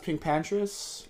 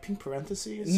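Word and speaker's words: PinkPantheress: It's pronounced Pink Pantress. – Pink Pantress. – I read, like, pink parentheses? – No. Pink Pantress.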